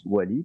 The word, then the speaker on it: Wally: mais Oli, qui est les 3 premières lettres, se prononce pas Oli, se prononce Wally,